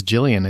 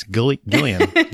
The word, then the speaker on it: Gillian: Everybody says Gillian. It's Gillian. Gillian Jacobs. Yep. – Yep. Just like GIF. – Yep. – Not JIF.